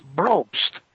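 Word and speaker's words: Brobst: It's B-R-O-B-S-T, pronounced Breust. – Oh, I thought it was B-R-O-E-S-T. It's B-R-O-B, like in Bob? – Yes, exactly, like in Bob, yes.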